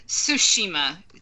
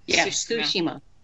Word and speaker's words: Tsushima: And also, Tsushima. – There you go. She was. – It's Tsushima. The T and the S is. – Tsushima.